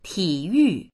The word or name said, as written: ti3yu4